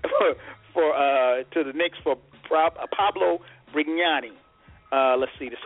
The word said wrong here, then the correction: Prigioni: Did I, did I pronounce that right? – No, Prigioni. – Oh, Prigioni. Eh, okay.